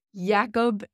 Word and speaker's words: Jakob: I think it's Jakob Ingebrigtsen. – Yes. – I don't think it's Jacob. I think it's Jakob, which sounds cooler.